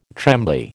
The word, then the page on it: trembly: American English